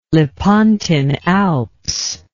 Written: Lepontine Alps

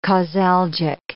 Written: causalgic

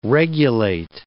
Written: regulate

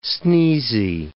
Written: sneezy

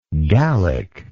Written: gallically

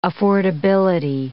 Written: affordability

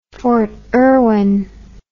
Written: fort irwin

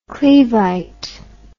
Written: cleveite

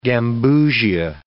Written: gambusia

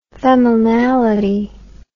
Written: feminality

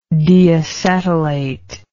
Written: deacetylate

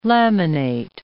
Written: laminate

